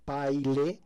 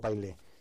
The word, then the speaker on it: Pailhe: Ah, el correo electrónico, sí, mejor es el correo electrónico, que es Gerardo, es mi nombre, Gerardo Paile, que se escribe P-A-I-L-H-E. Todo junto, Gerardo Paile. Gerardo Paile es P-A-I-L-H-E.